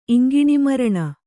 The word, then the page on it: ಇಂಗಿಣೀಮರಣ: Kannada